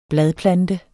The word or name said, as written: bladplante